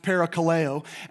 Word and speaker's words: parakaleo: The term urge in Greek here is pronounced parakaleo, and it means to demand earnestly or to demand pressingly.